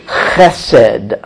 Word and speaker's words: chesed: That's one word in Hebrew, chesed, chesed. Would you like to say that? Chesed, chesed. Good, good. If you have a cough or something, you'll be better able to pronounce it. It's a huge word in its meaning. C-H-E-S-E-D, we could spell it.